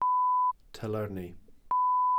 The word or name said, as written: tylyrni